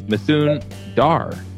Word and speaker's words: Mithun Dhar: We have a special guest, and that is Mithun Dhar.